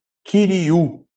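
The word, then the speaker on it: Kiriu (κυρίου): Ou, como a gente hoje ainda não pegou os ditongos, né? Kirioi. Ficaria, tá? Mas a pronúncia correta é Kiriu, tá?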